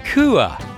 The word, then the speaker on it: Kooah: He released his first album entitled Kuh, K-O-O-A-H, the pronunciation of his last name, which upon looking at it in print had many people stumped as to how to say it.